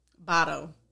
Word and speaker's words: Bordeaux: And no, Bordeaux. – Bado. That's how they pronounce it.